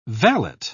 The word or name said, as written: valet